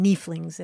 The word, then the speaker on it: nieflings: There's something about the long E in nieflings that I really like.